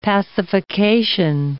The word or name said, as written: pacification